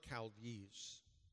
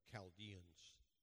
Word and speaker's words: Chaldees: And he lives in Ur of the Chaldees. CH in the Old Testament is pronounced K, like Chaldeans.